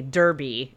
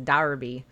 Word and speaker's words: derby: I read it as derby, but yeah, it's one of those words that you learn at some point that the English pronounce very differently than we do. Because yeah, we would just say derby, but it's a derby.